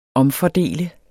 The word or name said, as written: omfordele